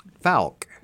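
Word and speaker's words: Fouke: Falk. – Yes. – That's how it's pronounced. Falk, Arkansas.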